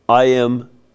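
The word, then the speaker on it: Impératrice: It's Association Imperatrice Zita, Imperatrice, which means Empress. – Imperatrice. Yeah. – Yeah, Imperatrice in France. I didn't say it like it's Latin.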